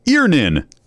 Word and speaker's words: Earnin: E-A-R-N-I-N is pronounced Earnin. I don't know how else you would pronounce it, but they really want to be clear that that's how to pronounce it.